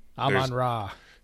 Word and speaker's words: Amon-Ra: Amon-Ra. – Amon-Ra.